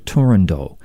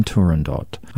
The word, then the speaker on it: Turandot: but scholars are now almost unanimous that the final T should not be voiced, so pronounce it Turandot rather than Turandot.